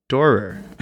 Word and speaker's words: Doerr: Doerr. Even when we worked together, I had a hard time pronouncing that. It's like the D-O-E right before the R.